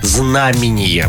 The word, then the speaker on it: знамение: Ну и сложное слово знамение, вот так оно звучит, а вы сказали знамение.